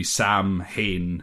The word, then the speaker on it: Samhain: Is that one of those brutal Gaelic pronunciations, yes, which is like when you read it, it's nothing like the way it's pronounced? – Yes, it looks like Samhain. – Yeah. – All one word, but like to kind of split it into two, it would be Samhain.